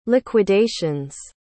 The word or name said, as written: liquidations